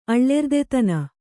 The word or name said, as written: ಅಳ್ಳೆರ್ದೆತನ